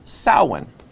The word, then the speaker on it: Samhain: No, it's actually, it's a Gaelic word, and it's pronounced Samhain.